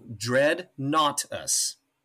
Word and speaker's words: Dreadnoughtus: Oh, Dreadnoughtus.